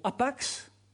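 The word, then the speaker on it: hapax: Le mot, et c'est intéressant de voir que le mot grec qui veut dire une seule fois, c'est le mot apax, est devenu un mot français que vous connaissez tous, qui est plutôt utilisé en linguistique. Et apax, avec un H, bon, que l'on ne prononce pas en français,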